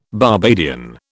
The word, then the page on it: Barbadian: British English